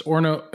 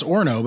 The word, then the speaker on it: Orono: Yeah, and so it's Orno. It's pronounced Orno, but it looks like O-R-O-N-O.